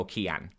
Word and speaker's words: Cian: I always say, how do you pronounce it? And they go, Cian.